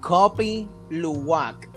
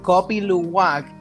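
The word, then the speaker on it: Kopi Luwak: Se llama Copy Luwak. Creo que estoy metiéndole, eh, diciendo eso horriblemente. pero el Copy Luwak es un café hecho de, la excreta de un animal.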